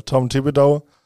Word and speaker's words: Tom Thibodeau: Tom Thibodeau.